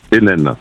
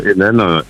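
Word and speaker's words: Heneine: Vincent Hennen, avec un H, H-E-N-E-I-N-E. Ça se prononce comme Hennen, N-N, mais on peut l'écrire Heineken aussi.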